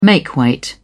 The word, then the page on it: makeweight: British English